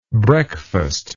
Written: breakfastless

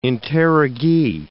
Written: interrogee

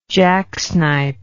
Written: jacksnipe

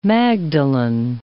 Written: magdalen

